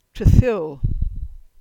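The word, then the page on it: Tredhywal: Cornish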